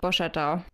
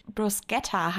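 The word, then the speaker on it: Borchetta: Ja, es tut mir auch leid, ich hätte es auch fast schon wieder so ausgesprochen. Ich dachte bis zum heutigen Tage, dass der Typ Bruschetta heißt, wie halt das Brot.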